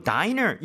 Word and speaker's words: diner: 그리고 diner, 이거 dinner가 아니라 diner 맞죠? – 맞아요. diner. 스펠링은 이제 dinner하고 비교하면 n이 하나 빠져 있죠? – 네.